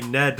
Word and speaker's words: Gned: I play Ned the Destroyer, who is a forest gnome wizard, and he's spelled G-N-E-D. – Yeah, G-N-E-D, yeah, the G is silent though,